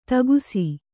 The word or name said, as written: ಥಕಾಸು